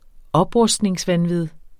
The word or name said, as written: oprustningsvanvid